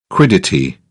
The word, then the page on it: quiddity: British English